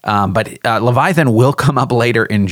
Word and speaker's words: Leviathan: Oh, Leviathan.